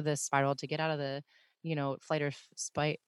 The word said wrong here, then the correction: flight or fight: I always say that wrong. Flight or fight response.